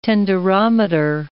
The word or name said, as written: tenderometer